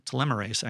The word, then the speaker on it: telomerase: Telomerase actually is how it's pronounced.